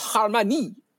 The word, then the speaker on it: Harmonie: Yes, I have to say it properly. The house of Harmonie. Yes, as the French say. – It is the deep, hard R's.